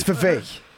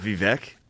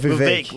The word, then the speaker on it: Vivek: I'm not thinking about Vivek when I'm nutting in these hoes, it's Vivek. – My bad. It's not Vivek. What? – Vivek, like cake. – Vivek? – Vivine's like rhymes with cake.